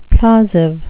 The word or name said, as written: plausive